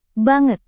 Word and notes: banget: indonesian

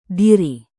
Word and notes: diri: indonesian

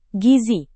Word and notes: gizi: Indonesian

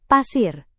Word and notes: pasir: Indonesian

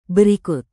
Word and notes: berikut: Indonesian

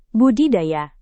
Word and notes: budidaya: Indonesian

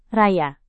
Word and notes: raya: indonesian

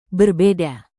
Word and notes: berbeda: indonesian

